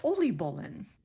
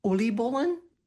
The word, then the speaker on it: oliebollen: From the Netherlands, we have oliebollen. Again, that is oliebollen. O-L-I-E-B-O-L-L-E-N, if that helps.